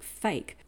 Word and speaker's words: PHEIC: PHEIC, which I presume is pronounced like fake, on the 30th of January 2020.